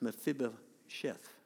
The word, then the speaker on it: Mephibosheth: No, it's not it. No, it's Mephibosheth. Is that what you said? It's a hard name to say, Mephibosheth.